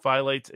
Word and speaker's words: phthalates: Yeah, somebody says in the locals chat, it's called phthalates and endocrine. Endocrine apparently is the pronunciation of the other word.